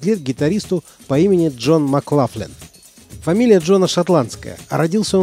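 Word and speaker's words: McLaughlin: В начале 60-х, когда молодой гитарист переехал в Лондон, его фамилию все еще произносили по-шотландски – Джон Маклохлан. Но в 69-м году он перебрался в Нью-Йорк. И с тех пор, как он сам с усмешкой говорит, стал Маклафлином. Так произносили его фамилию американцы.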